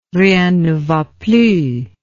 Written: rien ne va plus

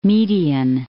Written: Median

